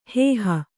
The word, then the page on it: ಹೋಹು: hōhu